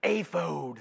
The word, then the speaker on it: ephod: and the correct pronunciation, according to this commentary in a way, is aphod, A-Y-F-O-D-E, aphod.